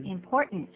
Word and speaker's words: important: 可是你刚念这个 important, 重要的，你还是念成 important. – 对。 – 那稍微快的时候就变成 important. Important. – Important. 用鼻音出来，特别记住。